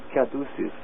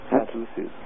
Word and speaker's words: Caduceus: Caduceus. Caduceus.